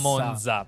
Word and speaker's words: Monza: Quindi TZ. – Esatto. – TZ. – Monza. Non Monza, perché ogni volta che dite Monza, muore un monzino.